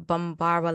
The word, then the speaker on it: Bambara: So in Bambara language, pardon me if I mispronounced that. – Oh, guys. – Go. No, you did it.